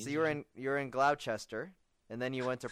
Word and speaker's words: Gloucester: Yeah, it's Gloucester, actually, yeah.